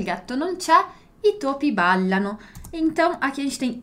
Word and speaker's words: ballano: Il topo balla, i topi ballano, d'accordo? Quindi, e poi qui la pronuncia della doppia L, ballano, ballano, dillo chiaro? Ballano.